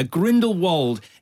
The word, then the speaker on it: Grindelwald: Yeah, Grindelwald is how I've been officially told by Warner Brothers it is pronounced, despite the fact that I personally prefer Grindelwald.